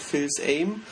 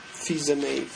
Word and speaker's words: Fils-Aimé: aber der heißt Reggie Fils-Aimé. – Ja, der wird von Nintendo, wurde auch im letzten Jahr, der heißt nicht Phil's Aim, den schreibt man nämlich Phil's Aime, genau, aber der ist äh, Fiese May.